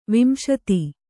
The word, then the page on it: ವಿಂಶತಿ: Kannada